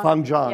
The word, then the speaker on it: Feng Zhang: Feng Zhang, yeah. Is that pronounced? – Right.